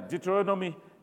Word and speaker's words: Deuteronomy: Deuteronomy, did I say it right? Yeah, Deuteronomy chapter 5.